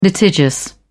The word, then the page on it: litigious: British English